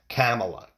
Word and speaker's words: Kamala: I'm just, I think it's Kamala Harris. It sounds Kamala. That's how it's said.